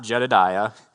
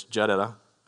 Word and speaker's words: Jedidah: Now, it's not Jedidiah. It's a woman. It's Jedidah, I think, is how you say it.